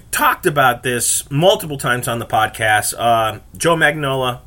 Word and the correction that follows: Joe Manganiello: I always fuck his name up. Sorry. You know, the big burly dude who is from True Blood. Played the vampire. Joe Manganiello. There we go. Knew I'd get his name right if I just powered through.